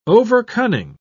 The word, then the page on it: overcunning: British English